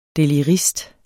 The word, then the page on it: delirist: deliˈʁisd; diləˈʁisd